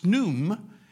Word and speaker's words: Khnum: It's spelled K-H-N-U-M, but it's pronounced Snum.